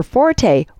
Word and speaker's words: forte: But if you means one's long suit, the thing someone does best, pronounce the word like it's spelled, fort, and just leave off the rest.